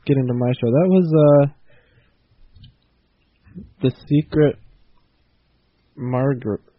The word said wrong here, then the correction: Magritte: spelled M-A-G... Oh, no, Magritte. Magritte? M-A-G-R-I-T-T-E. Pronounce it yourself. – Magritte. Maybe? – The Secret Magritte. Okay, by Larry Ox. O-C-H-S.